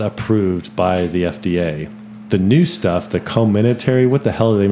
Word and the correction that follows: Comirnaty: Comirnaty. Comirnaty.